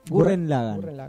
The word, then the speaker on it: Gurren Lagann: ¿Me ayudás con la pronunciación? ¿Cómo es? Gurren Lagann es... – Gurren Lagann. Tengen Toppa Gurren Lagann.